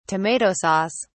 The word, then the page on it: tomato sauce: British English